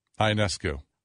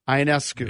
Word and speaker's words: Ionescu: how do you pronounce her name? Inescu. – Ionescu. – Ionescu.